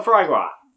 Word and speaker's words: foie gras: Or if you pronounce it the way it's spelled, foie gras. But, you know, French, they can't pronounce things the way it looks. So, foie gras. Foie gras.